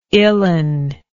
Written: illin

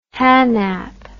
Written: hanap

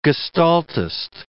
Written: gestaltist

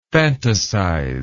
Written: phantasize

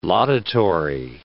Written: laudatory

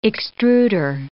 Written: extruder

